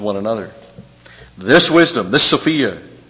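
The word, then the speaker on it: Sophia (σοφία): And he uses all through here for wisdom, Sophia. Sophia. Wisdom. Actually, that's not a long O, it's just an Omicron. Sophia.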